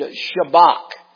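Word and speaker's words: shabach: The word is the Hebrew word shabak. I'm pronouncing it with English connotation on it. It's S-H-A-B-A-C-H. Shabak.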